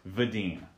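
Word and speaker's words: Wedin: By the way, it is pronounced Vedin. V sound for the W, And then, like, long E for the I.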